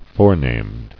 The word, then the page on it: Forenamed: fore·named